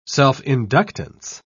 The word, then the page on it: self-inductance: British English